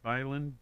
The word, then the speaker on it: Byland: 300 years to the year 1322 when Robert the Bruce of Scotland defeats King Edward II of England at Byland. Did I say it right? Byland, B-Y-L-A-N-D.